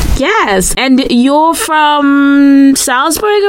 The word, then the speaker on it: Salzburg: Is it Salzburg or Salzburg? – Initially, before I came here, I was also pronouncing it as Salzburg. – Uh-huh. – When I came here, I think the dialect pronounces it as Salzburg. – Oh, maybe. Salzburg. Ooh, it's an ooh.